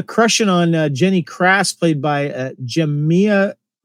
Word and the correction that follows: Jemima: Is that how you say her name? – J e m i m a Rooper. Yeah. – Jemima. Jemima. Jemima.